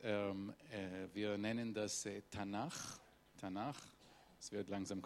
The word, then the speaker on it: Tanach: Und das zusammen, damit man es aussprechen kann, kriegt es ein Vokal. Und das heißt Tanach, sonst wäre das gar nicht durch den Mund äh, passierbar.